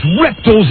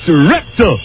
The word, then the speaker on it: reptile: If you got a commercial, like for FedEx, or you've got a movie you want to sell, and it involves reptiles, as Buzz Burbank would say. And I want to compliment your newsman for pronouncing it the right way. It's reptile. Absolutely. Not reptile, like all you Americans say. It's reptile.